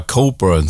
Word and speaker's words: Cockburn: Wenn man Bruce Cockburn spielt, muss man aber Coburn sagen.